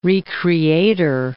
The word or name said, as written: re-creator